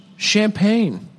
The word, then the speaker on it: champagne: Is this a residual haunting, or just maybe too much champagne. Champagans. – Do you want any of the champagans? – It's pronounced champagnes. Pronounced champagne.